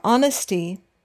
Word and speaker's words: honesty: But H is silent for honesty. The right path to tread.